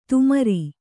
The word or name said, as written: ತುಮರಿ